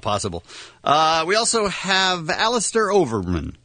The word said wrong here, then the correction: Overeem: Overeem. Am I saying that right? – I think it's Overeem, yeah.